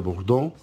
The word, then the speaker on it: Bordeaux: En francés Bordeaux se escribe, este, se pronuncia Bordeaux.